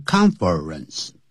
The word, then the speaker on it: conference: 好，Lisa 呢？她会什么？就怎么样呢？她就会带你呢，这个在饭店的门厅啊，这个呢，foyer 叫 foyer 啊，就是指的是我们通常是在酒店啦或者剧院的公共建筑物入口一进去那个门厅就叫 foyer。好，就是这样说的哈。好了，然后呢，再干什么呢？To the conference room，记住不要念成 conference，啊，这样要念成两个音节 conference，那个 confer 那个 er 它是不念出来